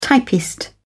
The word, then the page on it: typist: British English